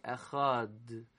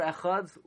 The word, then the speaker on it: Echad: By the way, you're not even supposed to say Krishma that way, because if you say Echad, you're making the D, and that's not the correct way to say it. Actually, Echad, you just say the Dalid as a Shva Nach.